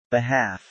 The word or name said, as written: behalf